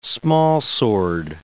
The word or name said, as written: smallsword